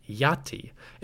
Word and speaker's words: Aten: Sharp-eared listeners will have noticed that Yati is quite close to what we think may have been the pronunciation for Aten.